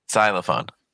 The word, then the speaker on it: xylophone: Xylophone. – Yeah, not. I don't think it's with an xylophone. – S. Yeah, like an X, majority of the time has a Z pronunciation.